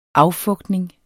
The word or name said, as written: affugtning